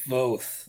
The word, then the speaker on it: Voth: I do believe it is pronounced Voth, though. I'd say 90% sure of that.